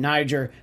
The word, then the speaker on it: Niger: Niger has the long I sound in it, and they're just really nervous for how he might say that word?